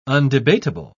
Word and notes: undebatable: British English

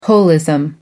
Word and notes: holism: American English

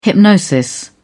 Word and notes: hypnosis: British English

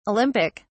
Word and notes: Olympic: British English

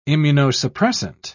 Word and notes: immunosuppressant: British English

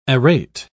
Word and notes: arete: British English

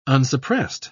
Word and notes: unsuppressed: British English

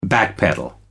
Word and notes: backpedal: American English

